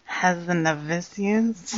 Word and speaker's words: Hazanavicius: Number 9 is The Artist, 2011, by Michael Hazanavicius. – That is actually the correct pronunciation.